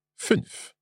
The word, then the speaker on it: fünf: Yes, for example, the number fünf. – Fünf. – Fünf. – That's five, yeah? – Genau. – And it's quite a short Ü.